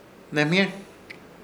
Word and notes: Hnemhyer: Ishir Chamacoco

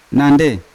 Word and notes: nandei: Yawa

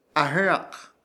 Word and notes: ah̃ɨrak: Ishir; Chamacoco

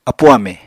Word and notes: apu amè: Apatani

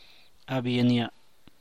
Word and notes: abanam: San Mateo Macuilxochitl Zapotec

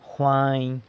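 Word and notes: jwany: San Lucas Quiaviní Zapotec

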